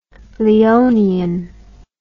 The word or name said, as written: Leonian